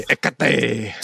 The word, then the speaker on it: Hecate: Except you wouldn't pronounce the H in Spanish. It'd be Hecate.